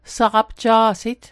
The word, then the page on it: saqapja'sit: Mi'gmaq